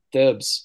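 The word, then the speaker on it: Tibs: I think I'm saying that right. – Yeah, Tibbs.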